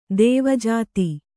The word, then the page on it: ದ್ವಿಜಾತ: dvijāta